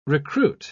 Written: recruit

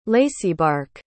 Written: laceybark

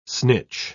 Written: snitch

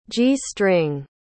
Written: g-string

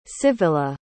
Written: civilla

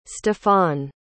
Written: stephon

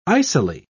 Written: icily

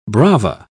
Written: brava

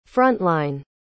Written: frontline

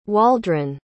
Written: Waldron

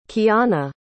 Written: qiana

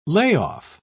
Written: layoff

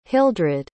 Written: hildred

